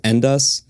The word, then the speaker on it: Endvs: One, and this is how I would pronounce it, just because it's spelt differently, is Endvs. It's spelt E-N-D-V-S, and I think they're using that V as a U.